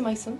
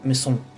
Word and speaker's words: Maison: Okay, ¿cómo se dice Maison? – Maison. – Maison. – Maison. – Maison. – Es como la mansión Kitsune.